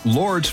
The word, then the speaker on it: Lorde: The silent E at the end is for no reason. It just looks cool. Her mom is an award-winning poet in New Zealand, which may explain why Lorde began writing fiction at first.